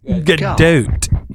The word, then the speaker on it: Gadot: Gadot.